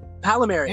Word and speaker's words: Pallamary: Pallamary. – Any Pallamary is a pal of mine.